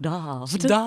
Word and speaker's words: daft: I like daft with the long A, like daft.